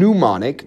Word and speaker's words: pneumonic: But bubonic and pneumonic, no pronunciation of the P on that, are the two times that we're going to focus on today.